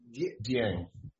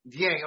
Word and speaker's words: Dieng: Dieng. – Dieng.